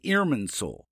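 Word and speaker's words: Irminsul: An important part of the beliefs of the Saxons at that time was an Irmin soul, a sacred pillar-like object. It's spelled I-R-M-I-N-S-U-L, S-U-L, Irmin soul.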